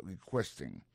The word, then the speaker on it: requesting: Dans le mot requesting là, ouais, accent tombé sur le deuxième syllabe là, requesting, politely.